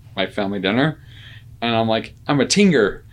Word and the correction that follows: tenor: And they're like, what? I'm like, yeah, I'm a tinger, like tinger, I think. She's like, my little sister's like, you completely said that incorrectly.